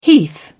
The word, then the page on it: heath: British English